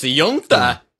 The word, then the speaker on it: Uinta: So I think it's Uinta. – But it's Yunta.